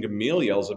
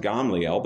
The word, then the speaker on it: Gamaliel: Gamaliel's a big deal. He's a big deal. Sometimes they pronounce it Gamliel, but it's the same dude.